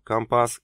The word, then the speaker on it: компас: Им не нужно было объяснять, что компас и рапорт произносятся с ударением на последний слог.